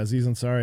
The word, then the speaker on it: Aziz Ansari: So like, what did Aziz Ansari do? Am I saying his name correctly? – So yeah, Aziz Ansari.